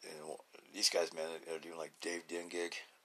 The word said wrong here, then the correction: Kindig: You know, did I say that right? Kindig, that's it, Kindig.